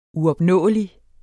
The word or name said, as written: uopnåelig